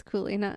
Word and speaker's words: Coulee: That's Coulee, right? Coulee. It's Coulee.